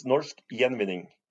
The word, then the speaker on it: Norsk Gjenvinning: Well, it's Norsk Gjenvinning.